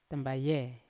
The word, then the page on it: tambaye: Yawa